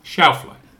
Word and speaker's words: Schauffele: Schauffele.